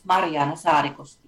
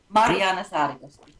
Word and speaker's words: Mariana Sarikoski: My name is Mariana Sarikoski. You did it quite well. – Marjana? Okay. – Mariana Sarikoski. – Mariana. – Like, not Mary Jane. Mariana.